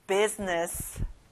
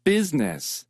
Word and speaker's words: business: business. 가운데 I 발음은 안 나요. business.